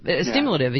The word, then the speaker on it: stimulative: It's not just helping people, which is what you know we believe in America when people are down, but it's also stimulative. Stimulative, as you say. I put way too many syllables in that word.